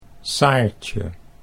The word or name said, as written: Saartje